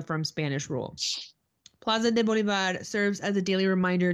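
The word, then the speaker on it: Bolívar: The central plaza is dedicated to Simon Bolivar, pronounced Bolivar, the liberator who played.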